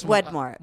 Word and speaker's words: Wedmore: the best person in the market is James Widmore. James Widmore. – Wedmore. That's how Wedmore. – It's spelled. – Wed, W-E-D-M-O-R-E.